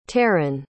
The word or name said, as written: tarin